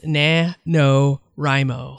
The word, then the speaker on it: NaNoWriMo: And this month is an event called NaNoWriMo, if I'm even saying that right. – No, it's NaNoWriMo.